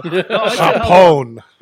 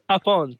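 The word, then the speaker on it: Japón: Did you say Los Ingobernables de Jamon? Because that's the ungovernables of ham. – Oh, Hapone. – Hapone.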